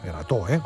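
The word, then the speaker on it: Erató: Y es que llega la novena edición del Erato Fest, Erato, con acento en la O.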